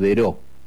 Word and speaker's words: Daireaux: Terrenos eran de un brasileño de origen francés que se llamaba Emilio Deró. Daireaux se escribe.